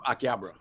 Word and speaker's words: Akihabara: Harabara arcade. A- Akihabara. Akihabara. My man. Akihabara.